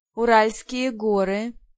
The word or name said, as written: Urals